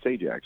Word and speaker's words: Sajdak: And it's, there's a D, there was a silent D in it. So it was S-A-J-D-A-K, but pronounced Sajak.